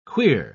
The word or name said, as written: queer